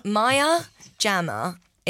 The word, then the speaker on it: Maya Jama: Maya Jama. Is actually how it's pronounced. But then I say Jama because I try and be posh.